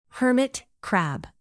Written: hermit crab

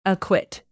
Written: acquit

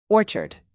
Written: orchard

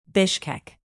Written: bishkek

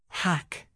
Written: hack

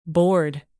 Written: bored

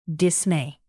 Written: dismay

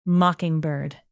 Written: mockingbird

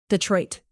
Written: Detroit

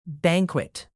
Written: banquet

